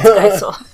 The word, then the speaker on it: Geisel: It's Theodore Seuss Ted Gazelle. I think I'm saying his last name wrong. – Giesel? – Giesel. Is that better? – How is it spelt? – G-E-I-S-E-L. – Geisel.